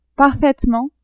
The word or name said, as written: parfaitement